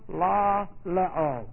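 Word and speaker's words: la leo (λαλέω): We have the verb la leo. L-A-L-E long O.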